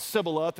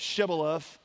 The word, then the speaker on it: Shibboleth: But when they said it wrong, when they said Sibboleth instead of Shibboleth, they slaughtered that person there at the Jordan River, and they killed 42,000 Israelites.